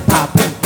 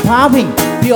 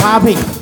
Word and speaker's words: popping: Popping 也会有人常常念成 popping，但是 it's not，it's popping，P O P P I N G，is popping。